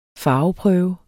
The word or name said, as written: farveprøve